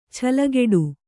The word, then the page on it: ಚಿಲ್ಲಗೊಟ್ಟೆ: cillagoṭṭe